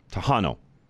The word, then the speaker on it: Tejano: I'm probably not saying that right, but I think it's Tiano. Tejano. T-E-J-A-N-O. Tejano. Am I saying that right, Charlie?